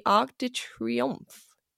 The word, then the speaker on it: Arc de Triomphe: On the last day of the trip, in Paris, my friend and I were wandering around a park near the Arc de Triomphe.